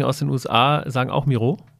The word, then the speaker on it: Miro: Tatsächlich wird es in Miro ausgesprochen. Also wir sind da nach dem Juan Miro benannt, dem spanischen Künstler aus dem Surrealismus, der ja tatsächlich auch sich da äh, ausgetobt hat und der Kreativität keine Grenzen äh, gelassen hat.